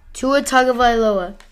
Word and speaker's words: Tua Tagovailoa: Tua Tagovailoa. – Still don't think how that's how you pronounce his name, but we're going to stick with it. Tua Tagovailoa go to.